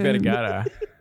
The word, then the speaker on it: Vergara: How do you say her last name? – Vergara.